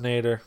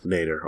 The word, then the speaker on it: nadir: Yeah, and it's pronounced Nader. – Nader. Okay, there you go.